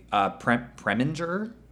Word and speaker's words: Preminger: And Otto Preminger, by the way, Preminger? – Yeah, that sounds about right.